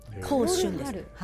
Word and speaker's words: 香春: その河原岳という香る春と書いて河原って読むんですけど、香春です。